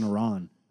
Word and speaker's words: Iran: They need to pay someone to be with you, like in Iran. I said it right. – Good job.